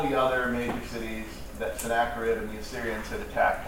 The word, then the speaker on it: Sennacherib: The news that he heard was that Sennacherib, that's how you say it, by the way, Sennacherib. I learned something interesting about Hebrew, is the CHs are just Ks.